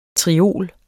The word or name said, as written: triol